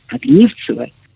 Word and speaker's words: Огнивцева: Обратите внимание на ударение. Огнивцева.